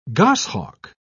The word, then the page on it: goshawk: British English